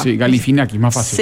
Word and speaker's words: Galifianakis: Sí, yo le saco solo la A, que es como supuestamente se pronuncia, pero es como Galifinaki, o sea, como un poquito más cerradito el asunto.